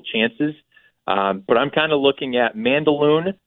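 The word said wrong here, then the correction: Mandaloun: uh, or I should say Mandalown, I guess is technically how it's pronounced.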